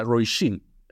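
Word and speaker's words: Róisín: Eh, es la Roisin, en irlandés se, pronuncia Roisin. Eso,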